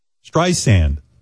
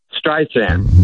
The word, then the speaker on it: Streisand: You were hitting the second syllable harder. Now you're not. Streisand, like you said.